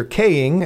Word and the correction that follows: Koenig: and I always say his name wrong, is 86 today, Star Trek legend. The Chekhov. – Oh, Walter Koenig, I believe. Koenig, that's how he pronounces it.